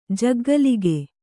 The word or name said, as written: ಜಗ್ಗಲಿಗೆ